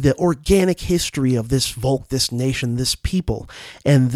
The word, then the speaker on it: Volk: In Germany, in Nazi Germany, the notion was the Volk, V-O-L-K, which in German, the V's are usually pronounced as the way we pronounce F's. So the Volk, right?